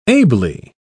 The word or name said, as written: ably